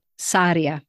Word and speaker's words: Sarria: Am I pronouncing that right? – If you could roll the R's, they'd be happier, but Sarria.